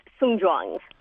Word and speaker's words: Songjiang: Sure, it's Songjiang.